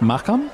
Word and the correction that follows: Mahakam: I thought it was Mahakam.